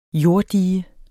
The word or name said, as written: jorddige